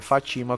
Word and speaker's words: Fatima: Ele tá falando que a pronúncia é Fatima com T, eu sei.